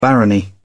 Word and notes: barony: British English

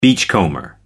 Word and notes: beachcomber: American English

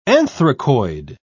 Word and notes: anthracoid: British English

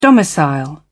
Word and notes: domicile: British English

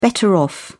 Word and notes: better off: British English

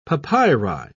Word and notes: papyri: British English

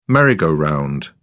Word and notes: merry go round: British English

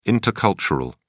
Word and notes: intercultural: British English

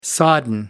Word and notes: sodden: American English

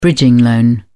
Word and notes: bridging loan: British English

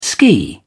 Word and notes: ski: British English

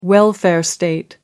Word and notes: welfare state: American English

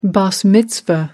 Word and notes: bas mitzvah: British English

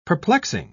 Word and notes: perplexing: British English

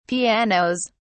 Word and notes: pianos: British English

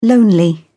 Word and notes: lonely: British English

